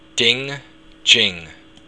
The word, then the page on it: ding: Irish